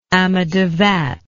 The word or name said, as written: amadavat